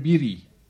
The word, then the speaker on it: Beatty: Y madre, yo me acuerdo que ese primer beso lo vi mientras veíamos una película que se llama, que trabajaba Warren, ¿cómo se dice? Beauty. – Warren Beatty. – Beatty, ¿cómo se dice? Beatty.